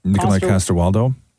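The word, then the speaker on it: Nikolaj Coster-Waldau: Nikolai Caster-Waldau.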